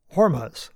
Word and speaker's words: Hormuz: Yep, that's the American pronunciation. Hormuz.